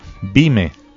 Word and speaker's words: Bime: Bime, tarima, aunque inicialmente se refiere a la tarima elevada desde donde se lee la Torah. Por extensión, también tarima teatral o escenario, del hebreo bima, en pronunciación yiddish.